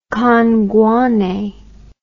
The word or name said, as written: kangwane